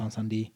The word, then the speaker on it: Incendies: It's spelled Incendies, Incendies. It's pronounced Encendie.